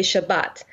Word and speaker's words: Shabbat: So there'll be people who say Shabbos, which is the Yiddish pronunciation, and then people who will say Shabbat, which is the modern Hebrew pronunciation.